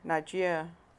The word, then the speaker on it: Niger: So, and you lived in Nigeria.